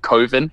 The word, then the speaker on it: coven: I love that he calls it Coven. – Coven? – It's you know, Coven. Like, yeah, exactly. – Well, that rhymes with oven. That can't be right. – Yeah. It's like, no, it's Coven.